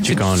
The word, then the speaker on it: Ciccone: Ciccone. – Ciccione. What is it, sir? – Well, in Italian, it works the other way. So the double C's are hard C's and the singular C's are soft C's.